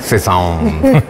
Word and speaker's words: Cézanne: ¿Cómo se pronuncia? – Cézanne.